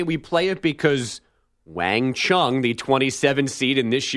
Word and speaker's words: Qiang: Now, it's spelled, Chung is spelled Q-I-A-N-G, but pronounced Chung. So her name is Wang Chung.